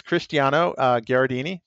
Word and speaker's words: Cristiano Garradini: His name is Cristiano Garradini. Did I pronounce that right? – Cristiano Garradini. – Okay, good. – Got to get both R's sounded out there.